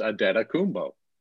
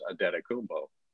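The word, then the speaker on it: Antetokounmpo: But, you know, the Milwaukee, their media guy, their media people tell you how to pronounce. And we asked their people, you know, and they said it's Adetokunbo. You know, all the Bucks people. But I hear national broadcasts. And JT and I talk about it all the time about how, why is that one so difficult to get everybody on the same page? Because apparently that's the way the American pronunciation is Adetokunbo.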